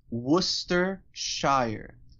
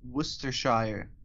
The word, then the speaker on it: Worcestershire: So according to the Merriam-Webster Dictionary, skip pronouncing the first R altogether and the CE while you're at it, and barely say the second R. Start off the word by saying Worcester, and then finish off with the British Shire, Worcestershire.